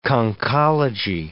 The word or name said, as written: conchology